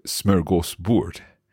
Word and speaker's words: smörgåsbord: Now, Hanna, I think that some of our listeners may have heard of the word smörgåsbord. Have I said that correctly? – You said it perfectly, and I think it's one of the most famous words for, in Swedish.